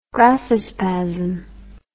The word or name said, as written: graphospasm